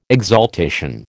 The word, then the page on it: exaltation: American English